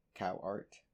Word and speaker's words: Cowart: February 6th, 2020, she dropped off her boyfriend, Jason Roberts, at his residence on Cowart Road in Plant City. I'm probably pronouncing that incorrectly. Cowart. C-O-W-A-R-T. Cowart, yeah.